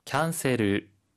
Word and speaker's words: キャンセル: キャンセル、キャンセル。 – キャンセル、キャンセル、 취소 란 뜻 입니 다。 혹 시 발음 틀 있을 까 요？ – はい。サービス 는 서 가아 니 라 사。キャンセル 는 캔 이아 니라 キャン 이 라 고 발음 합니 다。 그 리고 끝 에 니 을 받 침 은 르 라 고 해야합 니다。